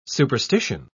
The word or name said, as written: superstition